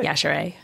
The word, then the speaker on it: Yashere: Yashere. Did I say that right? – Yashere.